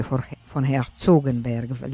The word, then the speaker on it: von Herzogenberg: dell'autore tardo romantico von Herzogenberg, o forse von Herzogenberg, l'accento lo metterei così,